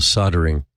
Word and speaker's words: soldering: And this is what in the US we call soldering, even though it's spelled soldering.